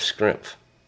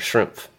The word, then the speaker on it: Schrempf: Yes, and maybe Detlef Schrempf, or Shrimp. How do you say it? How's the last name? – Shrimp. – Mm-hmm, Shrimp. – It's like Shrimp, but with an F on the end, somehow.